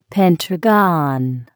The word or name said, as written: pentagon